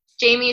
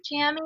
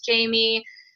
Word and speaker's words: Jami: Jamie is spelled J-A-M-I. It's like Jammy. I always get made fun of. They're like, is your name Jammy? I'm like, no, it's Jamie.